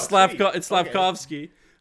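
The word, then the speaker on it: Slafkovský: You nailed the last name. – Really? – Yeah, it's Slavkovsky. Okay. But the J's are once again silent, and it's Yuri Slavkovsky.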